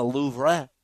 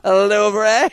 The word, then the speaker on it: Louvre: are just the things that hang in the Louvre. Is that how you say it? I don't even know. – Sure. – You see what I did there? – Yeah. – Louvre.